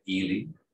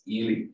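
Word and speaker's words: Ehle: And that is played by Jennifer Ely. I got the name right. Quite a few people pronounce it as Ely.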